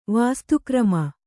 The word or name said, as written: ವಸ್ತಿಕರ್ಮ